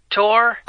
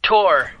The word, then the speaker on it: Tor: My name's Tor. – Achula. – No, Tor. – Oh, wait. – Wow. You have to spell it. – T-O-R. – E-R-J-E-E. – Just T-O-R, Tor.